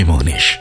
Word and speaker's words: Mohnish: मोहनिश नाम की स्पेलिंग है RJ M O H N I S H